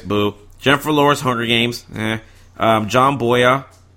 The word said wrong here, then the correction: Boyega: Boyega. Boyega, I say his name wrong over and over.